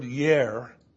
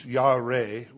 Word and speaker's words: yare: The word for fear that's used in the passage here in Psalm 76 is the word yer, or actually it's yare. It's spelled Y-A-R-E, but it's pronounced yare.